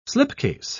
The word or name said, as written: slipcase